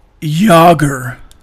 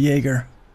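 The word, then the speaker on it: Jágr: My apologies to Jager. Jager. Instead of Jaeger. Jager. This texter says, out here pronouncing a hockey player name like a shot at the bar that ends in bomb.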